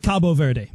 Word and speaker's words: Cape Verde: So coming up, how do you pronounce Cape Verde? – So you can either say How do you pronounce Cape Verde? – I like Cabo Verde.